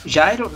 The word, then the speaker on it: Gyro: Mas Jairo na pronúncia. A pronúncia que era G-Y-R-O.